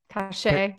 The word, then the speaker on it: Caché: And I completely said that wrong because it's supposed to be French. – Oh, C-A-C-H-E-E. – Caché. – Caché. Well, it's C-A-C-H-E with a little thing on top.